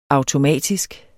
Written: automatisk

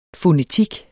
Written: FONETIK

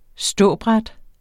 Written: ståbræt

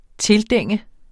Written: tildænge

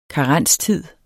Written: karenstid

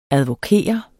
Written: ADVOKERES

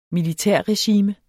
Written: militærregime

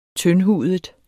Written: tyndhudet

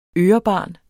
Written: ørebarn

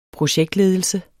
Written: projektledelse

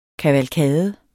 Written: kavalkade

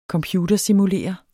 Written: computersimulere

